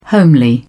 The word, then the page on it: homely: British English